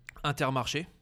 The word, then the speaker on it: Intermarché: Intermarché, how do you say that? – Intermarché.